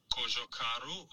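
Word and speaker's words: Cojocaru: Want zo spreek je dus de naam uit. Kojokaru ja, Kojo Karu. Kojo Karu.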